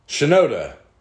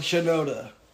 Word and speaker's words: Shinoda: Shioda. – Mike Shinoda. – Did I pronounce it right that time? – Shinoda.